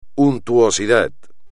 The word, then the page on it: untuosidad: espagnol